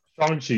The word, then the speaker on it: Shang-Chi: Shang-Chi. Have I been saying Shang-Chi? – Shang-Chi, you saying it.